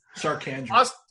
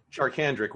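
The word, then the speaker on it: Charcandrick: Chartrandic? How do you say it? – Charkandrick. – You were great. What is it? – It is Charkandrick West.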